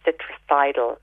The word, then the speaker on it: Citricidal: So it's sold under the name Citricidal um, by a company called Higher Nature. They do a liquid one, um, and that's spelled C I T R I C I D A L, citricidal it's a very good natural antifungal.